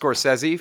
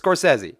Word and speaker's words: Scorsese: By the way, it's pronounced Scorsese for anybody out there who was wondering if it's Scorsese. It's Scorsese. But tomato.